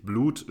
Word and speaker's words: BLVTH: Ähm, er spricht sich Blut, schreibt sich aber B-L-V-T-H, also statt dem U ein V.